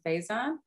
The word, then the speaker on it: Faison: Tell me about casting Frankie, and correct my pronunciation if I'm wrong, Faison. – Mm-hmm. Yeah.